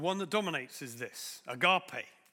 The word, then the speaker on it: agape: Well, many of us pronounce that as agape. But the Greek pronunciation is agape.